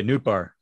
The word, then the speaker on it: Nootbaar: I heard somebody, I think Lars Nootbaar, is that right? Which, did I say it right? – Yeah. – Okay, Nootbaar. He sounds like a candy bar.